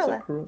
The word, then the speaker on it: Cruella: Cruella? Is that how you pronounce Cruella.